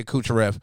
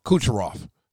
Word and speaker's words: Kucherov: I seen the stats on Nikita Kucherov. I'm saying it wrong. Kucherov.